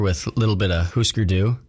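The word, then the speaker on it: Hüsker Dü: And I always feel stupid saying that name because it's spelt Husker, but it's got the, like, the Scandinavian double dots over the U. So I hear other people say Husker, and I want to sound cool and fit in with all the cool punk guys. So I say Husker too.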